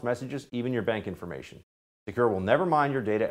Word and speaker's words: Sekur: That's why you need a new privacy and cybersecurity application called Secure. It's spelled S-E-K-U-R.